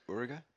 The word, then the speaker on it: Uerige: So the Dusseldorf one is is Uerige. – Uerige.